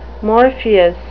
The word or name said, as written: morpheus